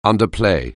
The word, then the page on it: underplay: anglais